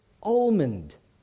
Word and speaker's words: almond: I always said almond instead of almond. Now, I still, for the life of me, can't work out why we don't pronounce the L if there is an L in the word.